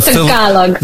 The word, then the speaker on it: Tagalog: They speak to me in Cebuano, but I respond to them in Tagalog. – Okay, so how do you say that? Tagalog. That is the Tagalog.